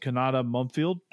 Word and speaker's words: Kanata Mumfield: I really like wide receiver, and I'm gonna butcher this name, but Kanata Mumfield. I think I got that. – That is correct, actually. – Did I get that right? – Yeah.